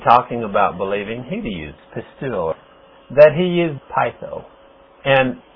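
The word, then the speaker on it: pisteuo: and my Greek students could tell you that that's pronounced pistuo.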